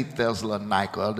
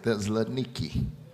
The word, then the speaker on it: Thessaloniki: When we were there, they didn't say Thessalonica. They said Thessaloniki. That's how they pronounce the name of the city.